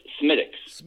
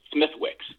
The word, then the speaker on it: Smithwick's: I believe it's Smittix. – Smittix. – And it looks like Smithwick's.